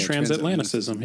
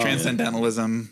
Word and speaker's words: Transatlanticism: Yeah, Transatlanticism, yeah. – Yeah, so I never say it right. Oh, Transcendentalism. Yeah. – I didn't even realize that you said Transcendentalism the first time.